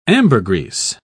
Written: ambergris

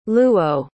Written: luo

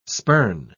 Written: spurn

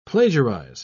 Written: plagiarize

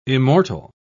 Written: immortal

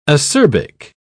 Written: acerbic

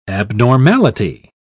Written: abnormality

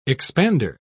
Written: expander

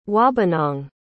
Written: wabanang

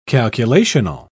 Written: calculational

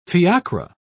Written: fiacre

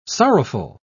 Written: sorrowful

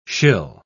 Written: shill

